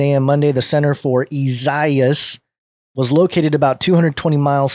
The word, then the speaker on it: Isaias: Isaias, pronounced Isaias, I guess I was saying it wrong.